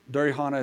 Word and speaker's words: Durihana: Durihana is a Korean word. Siri doesn't recognize it. It's spelled D-U-R-I-H-A-N-A.